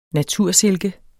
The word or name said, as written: natursilke